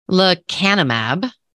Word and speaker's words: lecanemab: So, fun fact in January 2023, a disease modifying monoclonal antibody for the treatment of early Alzheimer's disease, lecanemab, um, I believe it's pronounced, received accelerated approval from the US Food and Drug Administration.